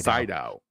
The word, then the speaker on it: Sydow: For the next round, we have Christoph Waltz, and we got Max von Sydow. I hope I pronounced his name correctly. – Max von Sydow. Sydow. – Sydow.